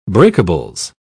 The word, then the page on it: breakables: British English